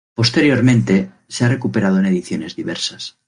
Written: ediciones

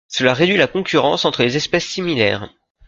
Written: similaires

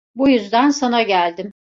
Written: sana

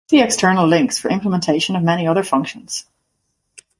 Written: implementations

